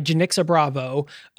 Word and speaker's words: Janicza Bravo: So this movie was actually written and directed by Jenixa Bravo, which, by the way, I looked it up. That is how you pronounce her name.